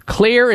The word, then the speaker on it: Xlear: Clear is spelled X-L-E-A-R. The X in the Clear name stands for xylitol, one of the ingredients that makes this product work so well.